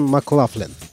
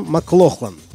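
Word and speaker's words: McLaughlin: В начале 60-х, когда молодой гитарист переехал в Лондон, его фамилию все еще произносили по-шотландски – Джон Маклохлан. Но в 69-м году он перебрался в Нью-Йорк. И с тех пор, как он сам с усмешкой говорит, стал Маклафлином. Так произносили его фамилию американцы.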